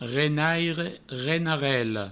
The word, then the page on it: renaire, renarela: renaire, renarello